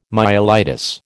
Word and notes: myelitis: American English

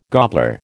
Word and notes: gobbler: American English

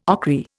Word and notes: ochry: Indian

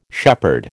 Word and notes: shepherd: American English

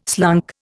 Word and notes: slunk: Indian

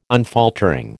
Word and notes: unfaltering: American English

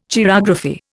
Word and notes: chirography: Indian

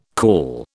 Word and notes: call: British English

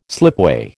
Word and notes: slipway: American English

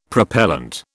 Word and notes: propellant: British English

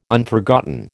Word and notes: Unforgotten: American English

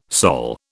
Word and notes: Sol: British English

